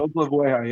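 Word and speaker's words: Oklevueha: And the pronunciation, you said Olkevueha is how you pronounce it? – Olkevueha, yeah. – Olkevueha.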